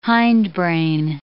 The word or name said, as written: hindbrain